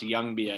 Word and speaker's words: Ljungby: But it's pronounced Ljungby, I guess. You know, the L is silent.